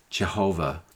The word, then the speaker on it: Jehovah: Some pronounce this name Yahweh, and most Christians pronounce it Jehovah.